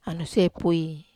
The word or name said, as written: aniose pui